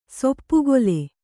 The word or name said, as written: ಸೊಪ್ಪುಗೊಲೆ